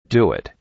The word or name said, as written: doit